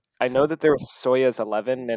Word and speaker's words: Soyuz: So Soyuz.